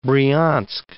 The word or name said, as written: Bryansk